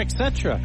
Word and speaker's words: et cetera: It is pronounced et cetera. E-T-C, et cetera. Et cetera. Et cetera. There's no ek.